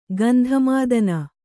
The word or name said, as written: ಗಂಧಮಾದನ